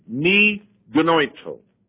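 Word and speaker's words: Mi Gnoito (μὴ γένοιτο): And it's pronounced this way. Mi Gnoito.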